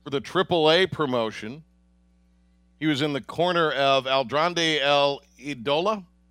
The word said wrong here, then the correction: Andrade: Oh, Andrade.